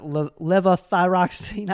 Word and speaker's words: levothyroxine: I was on I can't even pronounce it, levothyroxine. I know you pronounce That's it. – it. – Levothyroxine. That's right. Yeah. – Levothyroxine for a long time.